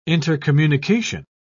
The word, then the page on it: intercommunication: British English